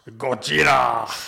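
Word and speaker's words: Gojira (ゴジラ): Eller Gojira! – Aha. – Det är det japanska uttalet.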